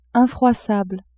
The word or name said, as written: infroissable